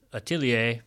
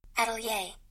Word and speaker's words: atelier: we walked over to Marc's atelier in Seoul's Fine Arts District in Gangnam. Hope I'm saying that right. – Atelier.